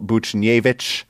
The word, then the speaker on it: Buchnevich: The woke mob is, I'm just joking, but I am being called out for my poor pronunciation of Pavel Buchnevich, which I have said correctly. I have pronounced the H in Buchnevich for the first time, and in doing, I am extending the challenge to our good pal Elon and Brian over at Keeping Carlson.